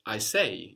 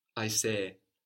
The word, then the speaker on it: aisee: So it's like A-I-S-E-E, and you know, the accent is on the end, which is probably why we have two E's, so we can kind of treat them like two syllables, no? And still stick by our rule of having the accent on the second last syllable. I say. It's like the accent's on the first E.